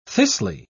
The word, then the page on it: thistly: British English